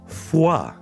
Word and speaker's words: foie: Ci hai mai fatto caso che la parola foie in francese contiene 3 delle 5 vocali esistenti e si pronuncia con le uniche 2 che non ci sono? – Ci risentiamo domani, sempre qui su Radio 105.